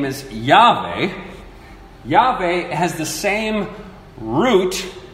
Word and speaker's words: Yahweh: It's the same name that is butchered and we say Jehovah, but that's not a good pronunciation at all. So it's the name Yahweh.